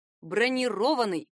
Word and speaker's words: бронированный: Броня — бронировать — бронированный. Обратите внимание, в этом случае глагол произносится с ударением на последний слог, а в образованном от него причастии ударение падает уже на «о».